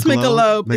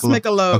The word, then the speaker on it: Michelob: Then I looked down at the small trash can in the garage, and lo and behold, an empty Michelob. I don't drink beer. I don't know how to say this. – It's Michelob. It's Michelob.